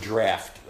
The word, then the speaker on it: draught: Rhymes with it's the same thing where draft beer comes from. It's the same word. We just spell it different. D-R-A-F-T, where you open that thing and let it come.